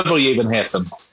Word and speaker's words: Övergivenheten: okay, yeah, even uh, Övergivenheten.